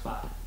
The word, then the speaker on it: fart: It's pronounced fart, but yeah. – Yeah, I know. Mm. I know, but it's how it's spelled.